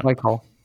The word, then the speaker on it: Baikal: Baikal.